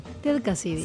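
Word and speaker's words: Ted Cassidy: Ted Kasidi. ¿Se dice así? ¿O cómo lo diría usted? – Ted Kasidi.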